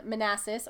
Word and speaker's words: Manassas: Well, my second one is actually closer to us because it's over in Manassas or Manassass.